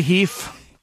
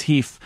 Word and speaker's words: HEIF: We have to say HEIF. That's okay. – How they say it. It's HEIF.